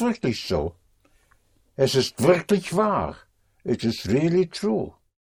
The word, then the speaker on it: wahr: War also means true. And that is spelled W-A-H-R, but it has the same sound, same pronunciation as was.